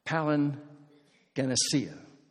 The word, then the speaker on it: palingenesia: It's a Greek word that's pronounced palingenesia. It's two words. Palin is the word for again. Genesis is the word for beginning.